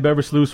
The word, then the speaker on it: Beversluis: And here helping us out with this preview of this 2017 Panthers, Brian Beversluis from – I pronounced that right, didn't I? – You did.